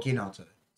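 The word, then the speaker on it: Chinotto: He said is it Kinotto or Kinotto? – Kinotto. – So not ch. – No. Silent H.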